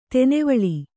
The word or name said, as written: ತೆನೆವಾಲ್